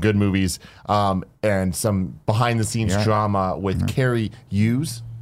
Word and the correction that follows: Cary Elwes: Is that how you say his name? Made the franchise worse. – Cary Elwes.